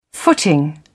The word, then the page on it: footing: British English